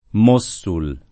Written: Mossul

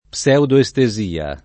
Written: pseudoestesia

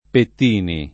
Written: Pettini

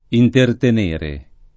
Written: intertenere

